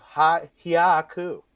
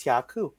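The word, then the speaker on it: hyaku: No, made up of hya-ku. Hya-ku. 100. – We are very multicultural here. – How you're pronouncing it is H-Y-A and K-U. So I think it's hya-ku.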